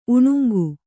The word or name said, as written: ಉಣುಂಗು